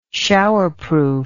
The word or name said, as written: showerproof